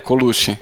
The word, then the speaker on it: Colucci: É Colucci mesmo, né? Teu, teu sobrenome? Eu pronunciei certo? É, Colucci. Colucci, né? Colucci, não é italiano. Mas é, acabou sendo, algumas gerações atrás, acabou sendo aportuguesado.